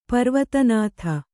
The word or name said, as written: ಪರ್ವತನಾಥ